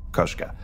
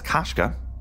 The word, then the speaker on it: Koshka: The O in Koshka is like the O in David Bowie. Type it into Google Translate to hear it. It's Russian. Koshka.